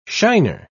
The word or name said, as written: shiner